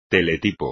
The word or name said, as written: teletipo